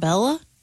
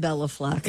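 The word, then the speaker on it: Béla: Born in 1958, the great banjo player, Mr. Fleck, first name B-E-L-A. – B-E-L-A. It's not Bella? – It's Bela. – Bela. Okay. – But growing up, we all said Bela Fleck. – Yeah. – Because we all said Bela Lugosi.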